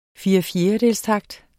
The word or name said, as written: firefjerdedelstakt